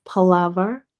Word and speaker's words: Palaver: All right, so it is Amethyst Palaver Hut, Amethyst Palava Hut, and that's the same thing on Instagram as well.